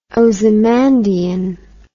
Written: Ozymandian

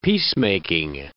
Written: peacemaking

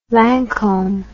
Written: Lancome

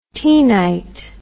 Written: taenite

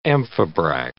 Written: amphibrach

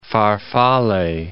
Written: farfalle

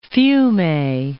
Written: Fiume

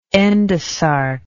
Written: endosarcous

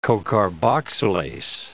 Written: cocarboxylase

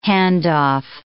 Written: handoff

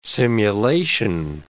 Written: simulation

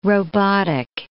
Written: robotic

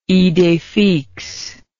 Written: idee fixe

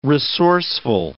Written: resourceful

